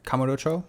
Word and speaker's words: Kamurocho: Kamurocho. – Oh, Kamurocho? – Yeah.